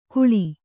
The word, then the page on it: ಘೂಳಿ: Kannada